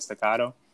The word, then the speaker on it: staccato: Staccato. Did I say that right? – Yeah, you got staccato.